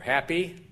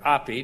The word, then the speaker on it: Hapi: The first one is Hapi, or Happy, or Api, depending on if you pronounce the H, H-A-P-I.